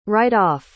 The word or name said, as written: write-off